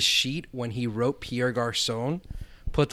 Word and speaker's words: garçon: You say it, garçon. You don't really pronounce the N too much. You just say garçon.